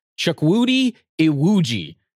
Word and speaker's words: Chukwudi Iwuji: It's supposedly Chukwudi Iwuji is how you say it. I had to look this up. Like I said, I looked them up how to phonetically say it.